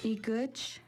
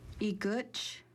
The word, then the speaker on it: Igutch: Igutch is how you say nose in Kixt. Igutch.